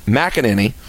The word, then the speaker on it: McEnany: And Kaylee, how do you say her last name again? – McEnany.